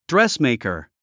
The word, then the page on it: dressmaker: drɛ́smèɪkɚ; ドレスメイカー